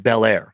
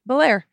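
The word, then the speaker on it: Bellaire: Yeah, emphasis on the second part. Because I was going to say it like California, the Prince of Belair.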